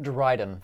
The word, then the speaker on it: Dryden: Star Wars Explained, so how do you pronounce it? – Dryden.